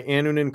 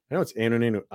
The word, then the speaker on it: Annunen: And I know it's Anunin, you know, maybe some people. I've heard it three ways on the broadcast. – I say Anunin, so sorry. I have the emphasis on the wrong syllable.